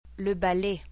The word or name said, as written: le balai